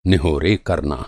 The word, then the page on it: निहोरा: Hindi